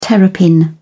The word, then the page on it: terrapin: British English